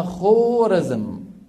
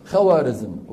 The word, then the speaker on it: خوارزم: عندهم بلد اسمها خورزم، شو اسمها؟ خورزم. فحتى يفرقوا، حتى ما يقرأها الإنسان خورزم، خو بالواو العربية، يضعون بعد الواو ألفا تكتب ولا تلفظ، بمعنى انتبه هذه خورزم.